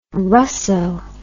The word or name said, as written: Russo